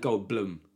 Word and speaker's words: Goldblum: Yeah, and I am saying Goldblum because I found out recently that's how it's pronounced.